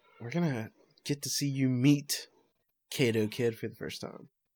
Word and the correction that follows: Kaito: Is that how you say it? Kaido? – Kaido Kid? – Kaido? – Yeah, Kaito Kid. – Kaito? – Yeah, don't bring up my mispronunciation. – We should have a Twitter poll. Sorry. – It's Kaito. – I'm interrupting. Kaito.